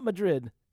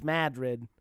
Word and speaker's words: Madrid: It's not Madrid. It's Madrid.